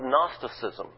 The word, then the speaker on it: Gnosticism: It's a form of Gnosticism. It's spelled G-N-O-S-T-I-C-I-S-M, I think, if I got it right.